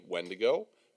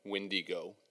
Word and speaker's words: Wendigo: and there's no right or wrong pronunciation, that's for sure. – Yes. I would say that the two most common are probably Wendigo, Windigo. Those are probably the two ones that you hear the most.